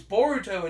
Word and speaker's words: Boruto: No, it didn't even say it, because I would think it would be like Naruto, it would be like Boruto.